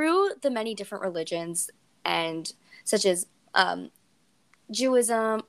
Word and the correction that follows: Judaism: Do I say? Did I say that right? Ju- Judaism. – Judaism. – Thank you. Sorry, so good. sorry. Judaism.